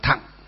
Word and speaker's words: thặng: Chữ thừa nguyên âm chữ Hán đọc là thặng. Chữ thừa nguyên âm chữ Hán đọc là thặng.